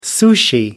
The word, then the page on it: sushi: American English